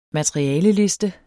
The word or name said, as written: materialeliste